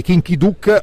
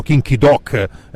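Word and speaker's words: Kim Ki-duk: ed è il film di Kinky Duck, o i ben informati dicono Kinky Doc, perché ormai bisogna dire così, se no ti dicono ma come pronuncia, non sai neanche il coreano. – Pronuncia coreana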